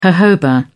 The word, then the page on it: jojoba: British English